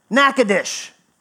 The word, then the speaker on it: Natchitoches: That is actually pronounced Natchitoches.